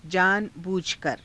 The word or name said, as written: jaana_buujhakara